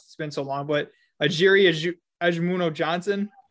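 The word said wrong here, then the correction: Ogemuno-Johnson: I hope I got that right. – Ojimono Johnson. – Excuse me. Ojimono Johnson.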